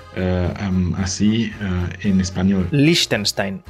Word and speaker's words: Liechtenstein: La palabra en alemán se pronuncia Liechtenstein. Liechtenstein. Quizás es porque no están eh, acostumbrados con la combinación eh, de las letras que siguen. Em, de un lado tenemos el I y el E que siguen, dos vocales así, y que hacen que el eh, I se pronuncia más lungo, Liechtenstein, Liechtenstein.